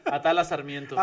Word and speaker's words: Atala Sarmiento: Atala Sarmiento.